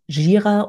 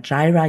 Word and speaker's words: Jira: Die heißen Jira oder Jira, je nachdem, wie man es aussprechen möchte, und Confluence mit C geschrieben.